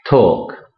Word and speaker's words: talk: Nota que el L no se pronuncia. Talk.